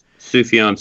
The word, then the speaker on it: Sufjan: Sufjan Stevens.